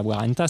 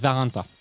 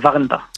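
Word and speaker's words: Warndt: Une première merde après à peine 5 minutes d'émission, enfin même pas, que dis-je 3 minutes, puisque j'ai prononcé Warrenta et que comme c'est allemand, non, c'est pas Warrenta, c'est Warrenta. – Ouais, c'est Warrenta. – Voilà, Warrenta.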